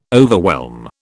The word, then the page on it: overwhelm: British English